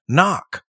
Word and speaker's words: knock: So today we're going to talk about this and other uses of the English word knock, which is spelled K-N-O-C-K, or of course, K-N-O-C-K. – Yeah, thank you for spelling it. That K is silent.